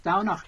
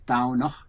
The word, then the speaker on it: Domhnach: dánach is spelt Domhnach. So it's a bit like Samhain. The MH becomes a W. So it's dánach.